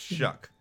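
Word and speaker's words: Shough: Shuck. which is spelled S-H or S-H-O-U-G-H. I don't even, did I mess that up? – Yes. Yeah. – Yeah. I got that right. Okay. Which is spelled like shock.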